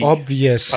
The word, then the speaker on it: obvious: ich spreche mal aus, oblivious, also offensichtlich fake. Obvious.